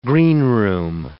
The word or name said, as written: greenroom